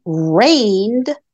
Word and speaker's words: reigned: They exercise this power, such as the king reigned over his people for 40 years. R-E-I-G-N-E-D. The G is silent in this case.